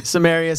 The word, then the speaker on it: Samaria: Am I saying it right in English? – Yeah. Samaria, Samaria.